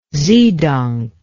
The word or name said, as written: zedonk